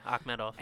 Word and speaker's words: Akhmedov: Omari Akhmedov, is that how you say it? – Yeah, Akhmedov.